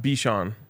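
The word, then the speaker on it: B'Vsean: So that's Vonnie Bavashan Miller Jr. It's pronounced Bichon. – Bichon. Oh, you don't say the V? – Yep.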